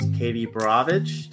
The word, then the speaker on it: Borowicz: And we're back with our guest, Katie Borowicz.